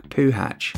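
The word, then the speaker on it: Puchacz: Poland's Timoteusz Puszas, which when pronounced properly, sounds like Poohatch.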